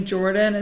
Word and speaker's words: Jordin: And my name Jordan is spelled with an I-N, so it's J-O-R-D-I-N.